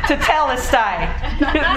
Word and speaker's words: Tetelestai: How do you pronounce that again? Yes, okay, thank you. Tetelestai. There you go.